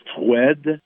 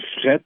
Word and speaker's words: froid: Par exemple, euh, le mot froid, F-R-O-I-D, se prononçait froid dans le temps. Ça a devenu fret au Québec et froid en France. Et c'est simplement le même mot prononcer différemment.